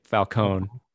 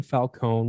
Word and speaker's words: Falcone: Carmine in Batman Begins, they pronounce it Falcone, but in this pronounce it Falcone. – Okay, yeah. So you have Carmine Falcone.